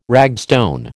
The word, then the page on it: ragstone: American English